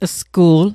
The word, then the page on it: sgu'l: Mi'gmaq